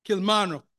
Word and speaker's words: Kilmarnock: she's married to a man from Kilmarnock, if I pronounce that name correct, Kilmarnock, okay, thank you very much.